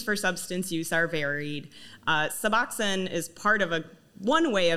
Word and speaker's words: Suboxone: So turning corners a little bit, I've been hearing about this drug Suboxone. Am I saying that correctly? – Suboxone? Yes, okay. Suboxone.